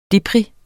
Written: deprim

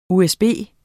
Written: USB

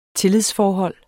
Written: tillidsforhold

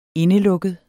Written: indelukket

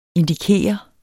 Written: indikere

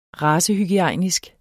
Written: racehygiejnisk

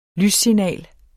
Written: lyssignal

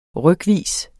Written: rykvis

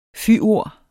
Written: fyord

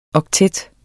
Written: oktet

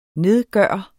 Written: nedgøre

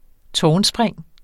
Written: tårnspring

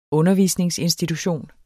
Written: undervisningsinstitution

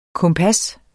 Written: kompas

